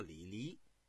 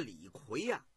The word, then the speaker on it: 李逵: 刚才咱们说了一个人物，我说的叫李黎，其实这是一个多音字，这个呢是个多音字，也念魁，平声。念这个李是这个三声，应该在这人名里头念魁。这个李逵他是嬴姓李氏，是战国时期的魏国人。